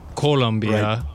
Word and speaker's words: Colombia: Well, so many people, Colombia, living there for a couple of years in Columbia, Missouri, Colombia would be the country. There's an O instead of a U.